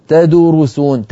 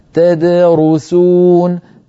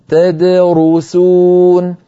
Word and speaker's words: تدرسون: فالدال تد ساكنة مقلقلة والرو مضمومة، إذا ضم الشفتين للراء وليس للدال قبلها، فلا يسرعن قارئ فيقول تدرسون تدو ويضم شفتيه عند الدال، هذا يؤدي إلى اختلال الصوت وانتقال الحركة من حرف إلى حرف قبله، فعلينا أن ننتبه وننطق هكذا تدرسون تدرسون وبما كنتم تدرسون، فنعطي كل حرف حقه من الحركة والسكون.